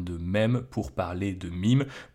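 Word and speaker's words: meme: aujourd'hui on va parler des mèmes ou des mimes. Hein. Je fais un petit point de prononciation pour commencer. Vous êtes au courant que c'est un terme anglais, donc on devrait le prononcer mime et non mème,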